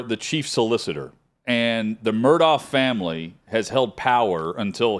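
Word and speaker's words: Murdaugh: I have a hard time saying Murdaw, the way they pronounce it. – Murdaw, like you said. It should be Murdaw, but the family mispronounces their own name. – Yeah. – Alex, it's A-L-E-X, and he tells everyone his name is Alec.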